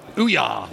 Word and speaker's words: Ouya: By the CEO of Oya. – Ouya. – Ouya. Is this an on-running joke, is it? – No, it's just how you pronounce it.